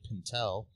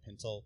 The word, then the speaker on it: Pintel: where he played Pintel. I think that's how you say it. Pintel? – Yeah, I think so.